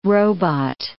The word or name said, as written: robot